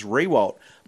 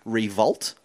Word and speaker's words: Riewoldt: But after the first season playing at AFL, he actually sent a nice letter to the commentators saying, well, it's actually pronounced Revolt, like the W is pronounced as a V.